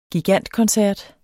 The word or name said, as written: gigantkoncert